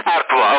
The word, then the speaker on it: Artois: Artois, ok, buona però come birra, non è né amara, è una via di mezzo, da buona.